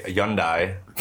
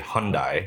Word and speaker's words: Hyundai: I drive a Hyundai, which is how they say it over there, but we say Hyundai because we're American.